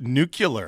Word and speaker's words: nuclear: As Halford says, nuclear. – Or etc. – Clear. – 7:30. – It's pronounced nuclear.